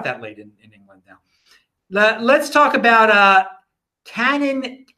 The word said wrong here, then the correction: Canaan: I'm mispronouncing their name. Canaan Creative, which is a miner that says they want to have an IPO in the United States.